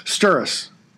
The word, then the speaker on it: Sturris: So, Sturris. – Yeah, like thermos. – Yeah, except no M.